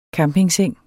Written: campingseng

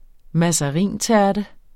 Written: mazarintærte

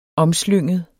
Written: omslynget

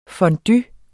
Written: fondue

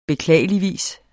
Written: beklageligvis

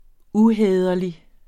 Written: uhæderlig

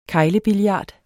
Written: keglebillard

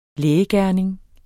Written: lægegerning

